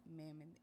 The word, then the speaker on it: Mammon: mammon is. – And I've never heard of that name. So what is that mammon? Am I saying that correctly? – Mammon. – Mammon. Mm-hmm.